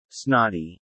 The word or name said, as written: snotty